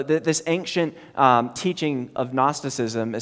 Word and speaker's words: Gnosticism: Silent G at the beginning, Gnosticism, and it was prevalent in the, in the time of the early church.